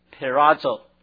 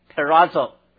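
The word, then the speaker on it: perazo (πειράζω): 那么所以这个字比较复杂啊，我希望你们能够记住这个字啊，甚至我教你们怎么念希腊文啊，叫做 perazo 啊，跟我来 perazo。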